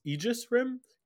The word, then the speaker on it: Aegis: To my embarrassment, I realized that it's pronounced Aegis Rim because I've been saying it embarrassingly wrong for my entire life because it's a word I never heard said.